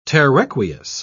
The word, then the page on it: terraqueous: British English